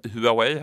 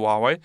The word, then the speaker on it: Huawei: de som vissa uttalar Huawei här i Sverige, men så ska det alltså inte sägas utan det heter Huawei.